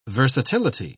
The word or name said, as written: versatility